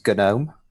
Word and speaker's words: GNOME: So the official way of doing it is GNOME. Because it comes from the GNU project. Although, to be fair, if you just say GNOME, I've said that for years, so I need to train myself, I reckon, to follow the branding of saying GNOME.